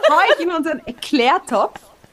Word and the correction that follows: Leclerc: Äh, Leclerc-Topf, jetzt habe ich es auch noch richtig ausgesprochen.